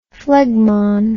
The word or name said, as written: phlegmon